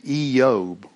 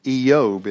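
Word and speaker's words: Iyov: And the word for Job in the Aramaic as well as in the Hebrew language is actually pronounced Eob, like double E space Y-O-B. Eob is how they say it.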